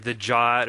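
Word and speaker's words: JWT: All right, so now that we've talked about the on-the-wire protocol, I had mentioned that we were using the JOT or JSON Web Tokens. JOT is like the pronunciation called out in the specification.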